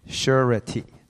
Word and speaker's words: surety: This is the definition, and it's pronounced surety. Like three syllables.